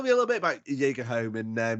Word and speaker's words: Jagerholm: Was it Jagerholm? – That's it, in one bang.